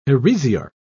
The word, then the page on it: heresiarch: British English